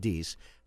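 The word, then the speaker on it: Deace: Stephen Deese, I believe is how he pronounces, Steve Deese, how he pronounces his last name.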